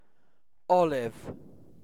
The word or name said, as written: olive